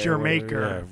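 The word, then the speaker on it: D'yer Mak'er: Dire Maker? Which Zeppelin tune? How does that go? – It's actually pronounced Dear Maker.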